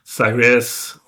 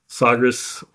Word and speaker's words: Sagres: Um, yeah, um, it, depending on who you talk to, it's Sagres or Sagres. I, I, you know, it, it's hard to know, really.